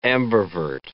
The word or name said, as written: ambivert